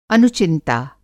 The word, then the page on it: अनुचिंता: Hindi